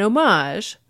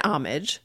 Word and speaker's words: homage: It is an homage. Or an homage, but many dictionaries note that homage is a relatively common pronunciation with this meaning, and there's some chance that it reflects a reborrowing from French, and that's why we have a pronunciation that is closer to the French.